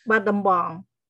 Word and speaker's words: Battambang: And you grew up in, I hope I'm pronouncing this correctly, Battambang? – Battambang.